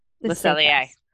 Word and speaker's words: Le Cellier: Le Cellier. – Le Cellier. Okay, I never can say it right. The Sailor.